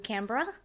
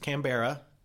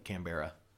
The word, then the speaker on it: Canberra: Yes, we went down to Canberra. – Yes, it's not pronounced Canberra.